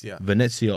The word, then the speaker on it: Venezia: Venezia away.